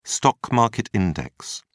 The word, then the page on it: stock market index: British English